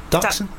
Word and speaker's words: Dachshund: by a poor Dachshund's life. Is it Dachshund? – Dachshund, that's it.